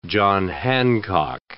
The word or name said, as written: John Hancock